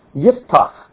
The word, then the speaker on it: Yiftach: Yiftach is how it's pronounced in Hebrew.